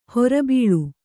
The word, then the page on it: ಹೊರಬೀಳು: hora bīḷu